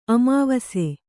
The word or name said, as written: ಅಮಾವಸೆ